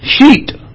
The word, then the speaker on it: Shet: Now, Seth's name in Hebrew is pronounced Sheet, S-H-E-T. And the word appointed in Hebrew is Shait, S-H-A-T.